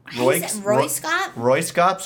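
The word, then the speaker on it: Röyksopp: How do you pronounce? Is that Roy? Roy Scop. So it's R-O-Y-K-S-O-P-P.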